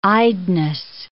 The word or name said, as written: eyedness